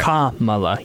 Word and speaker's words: Kamala: I'm still struggling with her name, but it's Kamala, right? – Yes. Okay. Kamala. Yeah, Kamala. – The emphasis is on the first one.